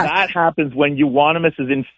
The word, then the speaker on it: euonymus: Is it unanimous bush? – Euonymus. – Say it again. – Euonymus. – Euonymus.